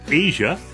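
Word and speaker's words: Aja: Steely Dan releases their sixth studio album in 77, pronounced Asia.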